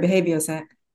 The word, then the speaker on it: BehavioSec: Is it BehavioSec? – No, you said it exactly right. BehavioSec.